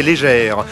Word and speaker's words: Léger: qui s'appelle Marc-André Légère. Ça s'écrit léger, ça se prononce légère, et vous allez voir que musicalement c'est très très bouleversant.